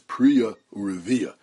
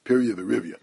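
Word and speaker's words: Priya Urivya (פריה ורביה): I should point out that the dikduk proper way to pronounce Piriyah V'Rivya is Priya Urivya. But the vernacular usage, the way I was always taught by my Rebbeim, was Piriyah V'Rivya.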